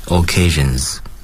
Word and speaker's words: occasions: 记住尾结尾的发音是 z，因为它的这个倒数第二个音节是浊辅音，嗯，所以呢 occasions。